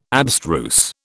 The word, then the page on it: abstruse: British English